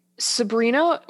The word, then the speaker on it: Sebhrenah: So we have uh, Sabrina, which is spelled in the most bizarre way I've ever seen the name Sabrina spelled S E B H R E N A H. Sabrina.